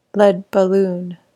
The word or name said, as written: lead balloon